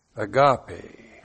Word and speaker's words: agape: The noun is agape. A-G-A-P, long E.